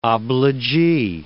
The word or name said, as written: obligee